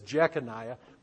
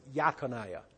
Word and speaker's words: Jeconiah: His full name was, you would read it as Jeconiah. They would have pronounced it as Yaconiah.